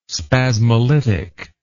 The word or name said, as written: spasmolytic